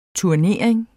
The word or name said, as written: TURNERINGERNES